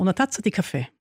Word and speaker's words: Una Tazza di Caffè: Da sind wir schon wieder beim Thema Kaffee, weil Sie haben ein Buch geschrieben, Una Tazza di Caffè. So richtig ausgesprochen, halbwegs? – Genau, Una Tazza di Caffè. – Nur schneller muss man sagen.